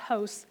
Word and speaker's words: hos: And so when we say as yourself, it's, what we're looking at, that word is H-O-S in the Greek, and it means, it says, it's pronounced host, H-O-S, host.